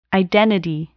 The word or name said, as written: identity